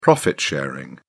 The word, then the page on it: profit sharing: British English